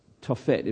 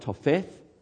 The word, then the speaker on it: Tophet: We're now being introduced to this concept of this place called Tophet. It probably looks like Topheth, but it's in Hebrew, TH is usually pronounced Tophet.